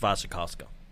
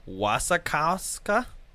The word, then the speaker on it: Wasikowska: No, it's Wasikowska. It's pronounced Wasikowska. It's Polish. – She's got an insane last name, so I just call her Mia. – Sure. – Yeah. It looks like Wasikowska.